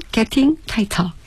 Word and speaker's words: Getting Tighter: 영국식으로 발음하면 Getting Tighter.